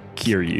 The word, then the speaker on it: Kiryu: Kiryu. Is that how you say it? – That's how you officially say it. Kiryu.